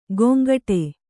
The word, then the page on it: ಗೊಂಗಟೆ: kannada